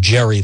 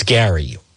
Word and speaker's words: Gary: People keep calling him Jerry Leonard. It's spelled like it should be Jerry. It's Gary, what have you.